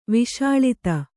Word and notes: ವಸೂಲಾತಿ: vasūlāti

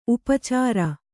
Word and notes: ಉಪಚಾರಿ: upacāri

